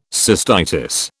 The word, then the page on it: cystitis: British English